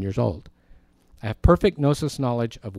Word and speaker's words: gnosis: And the Greek word for understanding is the word gnosis with the silent G.